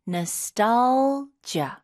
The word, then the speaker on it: nostalgia: 발음은 다시 한번 nostalgia. – 아, 첫 번째 A의 액센트가 좀 가면서 nostalgia 이런 식으로 하게 되면 뭔가 과거를 그리워하는 그런 네.